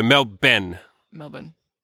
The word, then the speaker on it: Melbourne: So O-U-R-N-E in Australia is pronounced E-N. N instead of Orn. I know it should be Orn, and I don't understand, but, you know. – Australia. – So, Melbourne.